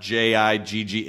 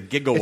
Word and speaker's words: gigawatt: So when a lot of people are surprised to find out that it's not JIGG, it's GI. It's a gigawatt.